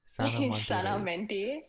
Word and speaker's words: Sanamante: Okay, how are you guys planning to help out people who are not mentally healthy through your Instagram page, Sanamante? Is that how it's pronounced? – Sanamante. It's a Latin word which means healthy mind.